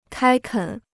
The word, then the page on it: 开垦: kāi kěn